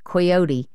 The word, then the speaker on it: Coyote: Do you say Coyote? – We say Coyote. – Coyote? – Yeah. – Even though it's spelt with an O.